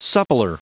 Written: suppler

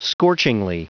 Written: scorchingly

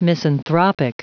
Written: misanthropic

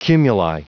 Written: cumuli

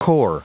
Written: core

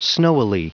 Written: snowily

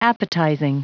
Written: appetizing

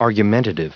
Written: argumentative

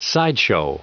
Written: sideshow

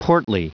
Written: portly

